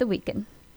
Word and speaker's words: The Weeknd: The Weeknd is originally named Abel Makonnen Tafai, born on February 16th, 1990, better known by his stage name, The Weeknd, pronounced The Weeknd.